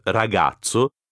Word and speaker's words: ragazzo: Le Z de ragazzo se prononce comme TS dans tsetse. Bien prononcer la double consonne.